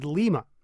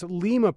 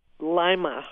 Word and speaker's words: Lima: It's spelled L-I-M-A, Lima, right? Wrong. It turns out, mistakes were made. And here to put them right is director of the Allen County Museum in Ohio. And it's Lima, Peru, but your town is pronounced how? – Lima. – Lima, of course. – Lima as like the bean. – Like a lima bean.